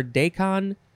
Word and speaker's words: daikon: I think some people might say daikon or daikon, but it is officially pronounced daikon, D-A-I-K-O-N, noun from 1876.